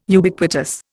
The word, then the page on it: Ubiquitous: Indian